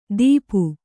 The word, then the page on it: ದೀಪು: dīpu